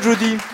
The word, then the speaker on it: Judie: Vous avez un joli prénom que vous prononcez à Judy. – Judy, c'est ça. À l'américaine. – Oui, c'est ça. Oui. – Parce que cela s'écrit J-U-D-Y ? – I-E. – Ah, J-U-D-I-E. Et on prononce. C'est vous qui souhaitez le prononcer à l'anglo-saxonne ? – Ah, c'est dans ma famille, on a toujours prononcé comme ça.